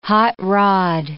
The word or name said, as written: hot-rod